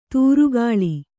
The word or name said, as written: ಧಾರೆಗಾಳೆ